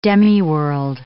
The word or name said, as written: demiworld